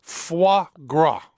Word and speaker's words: foie gras: Foie gras. Is that right? – That's very good. – Foie yes. – gras. – Foie gras.